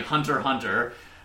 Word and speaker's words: Hunter x Hunter: After that, they made Hunter Hunter, which is spelled Hunter x Hunter, but is pronounced officially Hunter Hunter.